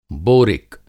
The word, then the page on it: बोरिक: Hindi